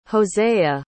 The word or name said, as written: hosea